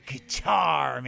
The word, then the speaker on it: Qatar: Qatar, man. – Qatar rhymes with guitar. – You know what? Actually, if you say it fast, it sounds like guitar.